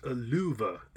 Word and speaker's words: lufu: The F is pronounced like a V. The first U is pronounced long. The second U is pronounced short. So it's luva, which is pretty close to love, I think, really.